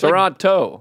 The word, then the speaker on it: Toronto: I say Toronto like a local. They don't pronounce the last T N or something. It's Toronto. Like Boston guys do that with mitten.